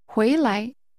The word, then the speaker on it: 回来: This is pronounced with a rising tone, followed by an unstressed neutral tone. – 请说。 – Return. – 回来。